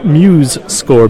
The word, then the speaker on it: MuseScore: Hi, I'm at the Muse Score booth and I'm talking to...